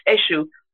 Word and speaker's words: Esu: It's Eshu. Eshu. – How do you spell it? – ESU, the Brazilians, because you know, many Yoruba went to Brazil. They spell it EXU. It's ESU.